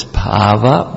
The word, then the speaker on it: bhava: What is bhava? Bhava with a long A means of your internal mood, and bhava with a short A means your existence in this world.